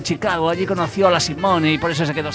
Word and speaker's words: Nina Simone: ¿Nina Simon? – No, Simone. – ¿Nina Simon? – No, Simone. – Nina Simone.